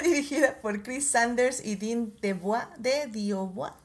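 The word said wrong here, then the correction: DeBlois: ¿cómo se dice? De Blois, de Blois. Es que Creo que es de Blois. ¿De Blois? Sí, ¿verdad?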